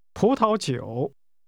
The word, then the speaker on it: 葡萄酒: It's pronounced with two rising tones, followed by a falling rising tone. – 请说。 – Wine. – 葡萄酒。